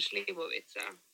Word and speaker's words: Slivovica: Alltså jag tror att ni fick en Slivovica. – Slivovica? – Ja, exakt.